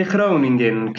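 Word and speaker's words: Χρόνινγκεν: Είναι Χρόνιγκεν ή Χρένιγκεν. – Είναι Χρόνιγκεν και καλά εδώ ναι, ναι. με τα Ολλανδικά, αλλά εντάξει. – Στα ελληνικά Χρόνιγκεν. Στα ελληνικά είναι Χρόνιγκεν, τέλο.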